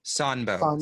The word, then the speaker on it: Sanbo: Actually, am I pronouncing that correctly? – Sanbo. – Sanbo. Sanbo Zen.